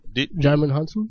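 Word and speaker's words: Djimon Hounsou: How do you say that guy? You know what I'm talking about? – Jaimin Hansu. – Is that how you say it? Yeah.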